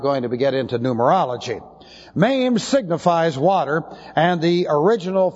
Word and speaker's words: Mem: Looks like this, MEM, M-E-M, long E.